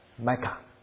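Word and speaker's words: 麦 卡 (מיכה): 所以如果用希伯来文念的话，他就是念成说神啊，麦卡，他的名字，他在念他自己的名，因为麦卡这个字就是有何神像你这个字。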